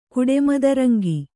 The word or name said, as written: ಕುಡೆಮದರಂಗಿ